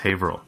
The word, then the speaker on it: Haverhill: No, it's spelled Haverhill, but it's Haverhill.